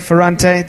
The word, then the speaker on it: Ferrante: Ferrante, sorry, Ferrante, the Italian way of pronouncing it.